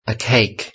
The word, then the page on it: a cake: British English